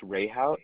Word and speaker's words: Rehhaut: It's pronounced Rehaut. It's spelled R-E-H-H-A-U-T.